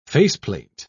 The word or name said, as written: faceplate